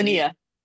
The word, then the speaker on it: Tanja: Tania, Jay is silent.